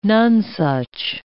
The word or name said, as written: nonesuch